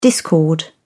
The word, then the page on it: discord: British English